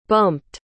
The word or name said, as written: bumped